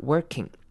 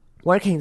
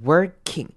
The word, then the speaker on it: working: Internet's not working。 Working 这个字之前也有提醒过，or 这边念成 er 的声音比较像是 er，那这边念 working。 Working 而不是 working，并不用把 or 的那个声音发出来，所以是 working。